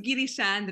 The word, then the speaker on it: Girisha: My name is Girisha, but you pronounce perfectly.